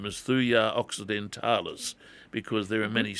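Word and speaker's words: Thuja: I would recommend a trial on Thujar.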